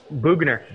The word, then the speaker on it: Boughner: Is it Bar? Boughner. Bug. See, there you go. Boughner.